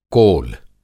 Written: कोल

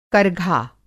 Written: करघा

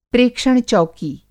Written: प्रेक्षण-चौकी

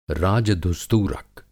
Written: राजधुस्तूरक

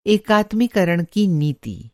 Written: एकात्मीकरण

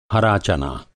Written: चना

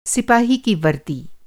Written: सिपाही

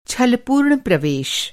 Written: छलपूर्ण

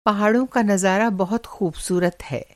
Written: नज़ारा

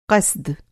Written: क़स्द

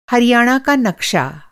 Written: हरियाणा

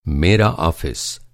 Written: ऑफ़िस

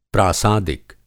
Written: प्रासादिक

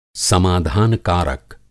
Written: समाधान कारक

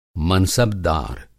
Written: मनसबदार

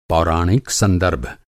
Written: पौराणिक

